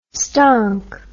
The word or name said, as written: stonk